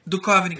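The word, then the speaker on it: Duchovny: Como é que fala o nome dele? – Dukovny. – Dukovny, cara. Dukovny? É, do Dukovny.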